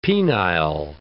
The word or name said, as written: penile